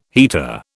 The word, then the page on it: heater: British English